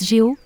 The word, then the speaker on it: GHO: Comme indiqué dans le communiqué d'AV, le GHO, se prononce GEO, peut être mint par les utilisateurs lorsqu'ils déposent des crypto-monnaies en tant que collatéral sur le protocole.